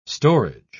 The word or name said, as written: storage